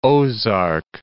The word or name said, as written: Ozark Plateau